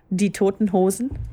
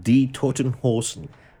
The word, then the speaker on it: Die Toten Hosen: Say it again. – Die Toten Hosen. – Die Toten Hosen. – I said it right. Yes. No, that was that uh, absolutely correct.